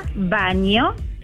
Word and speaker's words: Baño: ¿Cómo se pronuncia? Baño. – ¿Y cómo se escribe? ¿Bagno? – Belarga, no, Belarga, A-N-O. – Ah, igual que el baño.